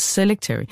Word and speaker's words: Selectory: Er det rigtigt udtalt? – Selectory. – Sorry, det trykket skulle lægges lidt anderledes. Selectory.